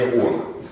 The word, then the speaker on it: Оно: Ударение на первое О. Во время Оно.